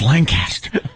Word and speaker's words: Lancaster: On my way to Lanchester. – No, you said it wrong. It's Lancaster. Right.